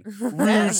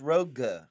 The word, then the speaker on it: Rogue: Rouge Squadron. It drives me nuts. – I don't understand that because it's rogue.